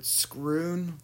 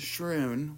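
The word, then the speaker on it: Schroon: So, we're headed toward Scroon Lake. It's not pronounced Shroon, which I think it should be, but Scroon. – Scroon? – S-C-H-R-O-O-N, I believe.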